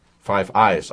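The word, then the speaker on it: Five Eyes: Das wäre ein weiteres Einfallstor zur Totalüberwachung der Bevölkerung durch die 5As. Den Geheimdienstverband aus den USA, Kanada, Australien, Großbritannien und Neuseeland. 5 Eyes eigentlich richtig ausgesprochen.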